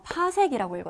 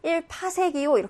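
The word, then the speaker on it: 파섹: 이거 파색이라고 읽어줘야 돼요. 1PC 이러면 안 돼요. 1파색이요 이렇게 해야지 딱 멋있는 거예요.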